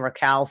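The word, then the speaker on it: Rakell: You know, Rackel or whatever, or Rake, what's his name? Raquel. Sorry, Raquel. I said his name wrong.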